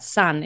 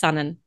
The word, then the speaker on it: Sonne: We were having a private little chuckle there because your handle is spelt S-O-N-N-E. And I asked, yep. Is that sun? And you said, no, it's sunnen.